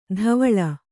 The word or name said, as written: ಧವಳ